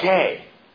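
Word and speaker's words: gay (γῆ): It's gay. If you're spelling that out, you wouldn't spell it G-A-Y. You'd spell it G-H-E-Y. Gay. It's kind of the way you would say that in the Greek.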